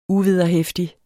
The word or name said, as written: uvederheftig